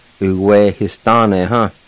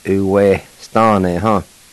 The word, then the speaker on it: Uwehistane: Uwehistane, huh? And actually, the I with the H is silent. You kind of just pronounce the H sound like this. Uwehistane, huh?